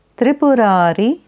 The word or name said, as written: Tripurari